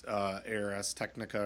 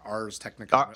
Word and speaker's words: Ars Technica: Ars, it's pronounced. I used to think it was ARS, but I listened to enough nerds talk about it that it's Ars Technica or just commonly referred to as Ars.